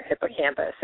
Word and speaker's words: hippocampus: It's hippocampus.